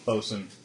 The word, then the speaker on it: boatswain: Bosun. – Bosun. – Highest ranking in... – Boatswain, a minor official of the crew. – Bosun. It's spelled boatswain, but it's not.